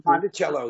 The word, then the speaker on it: Monticello: So that's Monticello, or, yeah, did I say it right? Monalto, Jefferson High Mountain. – Monticello, yeah.